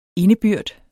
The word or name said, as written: indebyrd